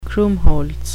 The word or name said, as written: krummholz